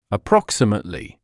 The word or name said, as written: approximately